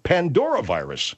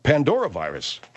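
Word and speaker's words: Pandoravirus: Even a panda virus. – Not a panda virus, man. Pandovirus. – It's actually, I mispronounced, it's a Pandora. P-A-N-D-O-R-A. Pandora virus. Pandora virus.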